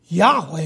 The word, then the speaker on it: Yahweh: Yahweh is probably the most accurate way to say it.